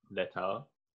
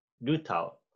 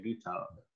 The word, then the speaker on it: LeTAO: Do you know how they pronounce it? Letao? Lutao. Yeah, it's spelled Letao, but they say Lutao. It's supposed to be like Otaru backwards.